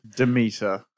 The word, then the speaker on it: Demeter: The Meg 2, The Trench, with 12.7 million, and Dracula, The Last Voyage of the Dementor, with 6.5 million. – Demeter. – Oh, did I say Dementor? – He hates this movie that much that he's just not even going to pronounce it correctly.